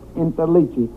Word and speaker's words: Interliggi: Well, my name is Joseph Interligi. I-N-T-E-R-L-I-G-G-I.